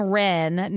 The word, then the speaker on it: Rennes: And because there's a character named Ren, not Renez, although it's spelled R-E-N-N-E-S, but it's French and it's pronounced Ren.